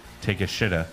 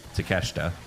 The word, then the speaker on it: Takeshita: I showed this name to a couple of my coworkers, and I was like, how would you pronounce it? They're like, take a shitta. – Yeah. – Yeah. – Well, that's, like, how, well, that's how you pronounce it. – Well, no, it's Takeshita.